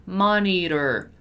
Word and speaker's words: monitor: In American English, as you know, many times with the pronunciation of the letter T is R, R, R, right? So monitor, monitor, monitor, monitor financial trends.